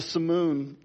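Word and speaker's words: simoom: There was a particular southeast wind called the Simoon, or the Simoon, some people pronounce it, that James was likely referring to and that everybody knew about it.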